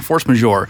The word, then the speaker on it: force majeure: Force majeure. – It's a law French term. I know people usually think law terms are all Latin, but we actually borrow a lot of legal terminology from French, and it's all butchered and anglicized and spelled terribly and pronounced wrong. So and we mispronounce it.